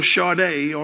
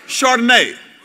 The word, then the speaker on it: Chardonnay: I know it is kind of chic and cool now to have your Chardonnay or your did I say that wrong? What is it? That's your wife in this. I'm sorry, Chad. What is it called? Chardonnay.